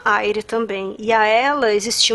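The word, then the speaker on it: Eire: esse Aire, né? Que se escreve como até hoje se escreve o nome da República da Irlanda, que é E-I-R-E, né? E se lê Aire.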